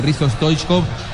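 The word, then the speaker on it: Hristo Stoichkov: Muy bien, seguimos compartiendo el 0 a 0 entre Corea y Argelia, pero la presencia de Risto Stoichkov está bien, ¿no?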